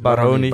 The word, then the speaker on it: Baroni: Gehandhaafd dus niemand en gepromoveerd uh, eh, 19 Rosmalen, TOGB en Baroni. Ja, als je... Eh, Baroni, denk ik. – Baroni, Baroni, Baroni, ja. Sorry, de klemtoon.